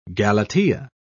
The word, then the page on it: galatea: British English